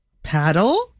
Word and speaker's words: Padel: And I don't know how to say this name, but it is spelled, this sport, P-A-D-E-L. – Paddle? – Padel.